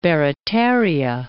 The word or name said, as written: Barataria Bay